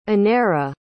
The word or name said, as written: Aneira